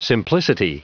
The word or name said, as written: simplicity